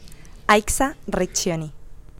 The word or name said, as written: Aixa Recchioni